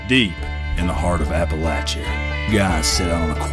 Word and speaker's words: Appalachia: It's pronounced Appalachia.